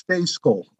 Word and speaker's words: Stejskal: It's pronounced nothing like it looks. It's Stayskull, like it was S-T-A-Y.